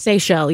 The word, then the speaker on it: Seychelles: Is it pronounced Seychelles? – Seychelles, yeah. – The Seychelles, the islands. – S-E-Y-C-H-E-L-L-E.